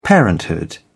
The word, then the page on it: parenthood: British English